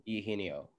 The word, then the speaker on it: Eugenio: I have Eugenio Suarez. I don't know how to pronounce his first name for some reason. – Eugenio.